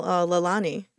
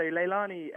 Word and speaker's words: Lailonie: You've pronounced it perfectly, actually. Most people get that wrong. But yeah, so Lailonie, it's named after my wife, Maddie. It's one of her middle names. It's a Hawaiian word, which means heavenly flower.